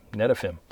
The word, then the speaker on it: Netafim: Netafim is spelled N-E-T-A capital F-I-M, Netafim.